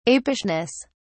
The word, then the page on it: apishness: British English